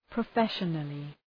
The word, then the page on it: professionally: prə'feʃənəlı